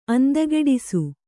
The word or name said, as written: ಅಂದಗೆಡಿಸು